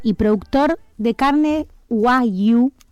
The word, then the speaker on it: Wagyu: Bueno, pero igual, viste que tiene diferentes formas para pronunciar, pero bueno, bien la forma correcta es Wagyu.